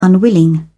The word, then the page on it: unwilling: British English